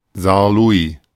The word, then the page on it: Saarlouis: German